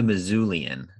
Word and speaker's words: Missoulian: He's with the Missoulian, is I believe how it's pronounced. So it sounds like you're saying Mizzou, but that's just the name of the newspaper up there in Montana.